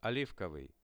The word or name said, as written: olivkovyi